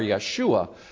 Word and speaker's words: Yeshua: And we know that Yehoshua, or Yeshua, as it's sometimes pronounced, is the same name as that of the conqueror of Canaan, Joshua the son of Nun.